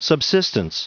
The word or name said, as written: subsistence